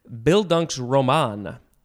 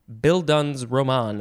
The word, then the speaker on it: Bildungsroman: All right, now we have, ooh, this is a fun one to say, Bildunk's Roman. Bildun's Roman, a couple slightly different ways. It is spelled B-I-L, D-U-N-G-S-R-O-M-A-N.